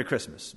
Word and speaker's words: Christmas: And I thought, you know, we really do pronounce it without the T. Merry Christmas.